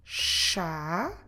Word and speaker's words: chá: They all start the same way with CH, and we will read them all with a soft sh in the at the beginning. So we have chá. Como se escreve chá? C-H-A com acento. Chá. Chef. Como se escreve chef? C-H-E-F-E.